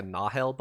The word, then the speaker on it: Nahel: the Nahel bond. – It's pronounced with a soft A, right. So it's like a Nahel bond is how they say it. Is how they say it in the audiobook.